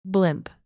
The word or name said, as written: blimp